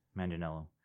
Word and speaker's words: Manganiello: Manganiello.